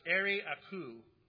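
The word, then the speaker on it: Arioch: Arioche is better pronounced Ari-Aku.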